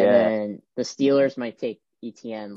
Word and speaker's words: Etienne: what, how do you say his name? Etienne? – Etienne. It's like weird.